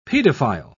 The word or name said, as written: pedophile